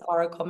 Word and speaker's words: Orykl: So it's pronounced Orykl, maybe slightly different, but, you know, you get it.